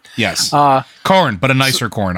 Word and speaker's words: Khorne: Is that Khorne? K H O R N E. – Khorne. That's how I would have pronounced it.